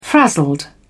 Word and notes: frazzled: British English

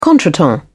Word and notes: contretemps: British English

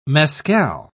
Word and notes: mescal: British English

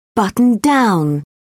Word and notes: buttoned-down: British English

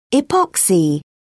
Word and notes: epoxy: British English

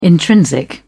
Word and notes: intrinsic: British English

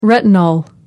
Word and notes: retinol: American English

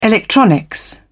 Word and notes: electronics: British English

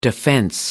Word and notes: defense: American English

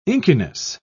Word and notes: inkiness: British English

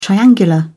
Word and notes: triangular: British English

